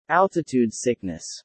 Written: altitude sickness

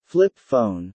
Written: Flip phone

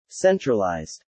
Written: centralized

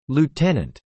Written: lieutenant

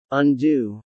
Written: undue

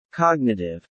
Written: cognitive

